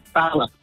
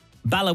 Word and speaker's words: Bala: How it's, Bala. – Bala with the short A, the northern vowel sound.